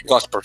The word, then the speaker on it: Gosper: Gosper, se llama, creo que se pronuncia él. Gosper, sí, pronunciado Gosper.